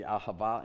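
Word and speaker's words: Ahava (אהבה): If you remember our ministry, Ahava, and you actually pronounced it correctly. I usually, most people can't, and Ahava is a Hebrew word for love.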